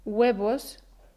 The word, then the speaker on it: huevos: ¿Sabías que cuando decimos huevos, no decimos huevos? Escucha bien, decimos huevos. Huevos. Oyes el sonido g.